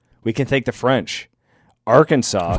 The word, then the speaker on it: Arkansas: The explorers met a group of Native Americans and they called them Arkansas, S-A-W. Since the letter S at the end of the French words is usually silent, we pronounce the home state Arkansas, and that's the reason why.